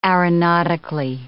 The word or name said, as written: aeronautically